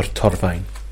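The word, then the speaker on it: Torfaen: Torfine? Or Torfine. I like how you say that. You say it properly.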